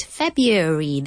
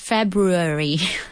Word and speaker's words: February: So I did, February, that's the American pronunciation, more or less, and the British one is February.